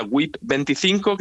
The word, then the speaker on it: WIP: Bueno, las siglas, se pronuncia WIP25, que son las siglas que corresponden a Work in Progress, ¿vale? – Vale.